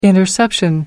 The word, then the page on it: interception: British English